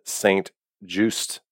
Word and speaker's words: St. Juste: On the board right now at the cornerback position is Shakir Brown and McPherson from Texas Tech, and then Benjamin St. Juste. I said that right, correctly, from Minnesota? – You did. You nailed it.